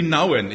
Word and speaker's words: Nouwen: Ich möchte einsteigen mit einer kleinen Geschichte, die ich vor kurzem gelesen habe von dem Holländer, Theologen, Philosoph, Henry, wie heißt der Annemarie? Nouwen? Henry Nouwen, ich habe den immer falsch ausgesprochen, habe ich heute Morgen festgestellt.